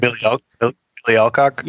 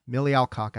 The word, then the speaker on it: Millie Alcock: Miley Alcock, I think that's her name, right? Did I pronounce that right? Correct me. – Millie Alcock? – Yeah, yeah. Millie Alcock, I called her Miley Cyrus.